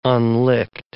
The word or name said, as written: unlicked